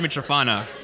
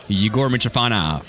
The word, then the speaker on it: Mytrofanov: Yegor Mytrofanov. – So hello. – Pull that mic right into your face. – Yegor Mytrofanov.